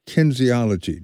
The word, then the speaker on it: kinesiology: And now that you are at Mississippi College, you're studying kinesiology.